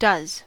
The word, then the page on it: does: American English